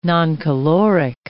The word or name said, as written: noncaloric